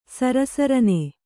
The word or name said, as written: ಸರಸರನೆ